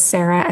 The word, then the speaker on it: Sara: Yes, it's S-C-H-A-I-R-E-R, Schairer is my last name, Schairer, and my first name is Sara, S-A-R-A.